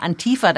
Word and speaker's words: Antifa: sagt man hier, Betonung auf der zweiten Silbe, Antifa dahinter stand, also dass das in wirklich Wirklichkeit Linksextremisten waren, die da äh, reinmarschiert sind und äh, Unheil gestiftet haben.